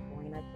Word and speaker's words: Karena: I am a person, I pay attention to names, my name being Karina, K-A-R-E-N-A.